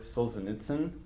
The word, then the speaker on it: Solzhenitsyn: And just to give you just a quick little bit, this is about a writer, kind of poet, Alexander Solzhenitsyn.